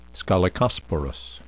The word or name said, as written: scolecosporous